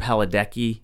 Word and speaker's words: Padalecki: I remember Jared Padalecki. Is that how you pronounce his name? The guy from Supernatural. – That's right.